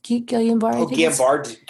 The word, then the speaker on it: Guillain-Barré: Guillain-Barré condition. Pronounce it right? Yeah. Yes.